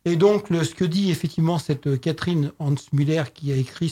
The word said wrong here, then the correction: Gans-Müller: ce livre, pas Catherine Hans-Müller, pardon, excusez-moi, j'écorche son nom, Catherine Gans-Müller, voilà,